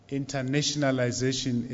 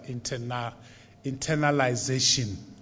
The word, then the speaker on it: internationalization: I see that internationalization is difficult to pronounce. I'm often called Deputy Vice Chancellor Internalization. It's internationalization rather than internalization.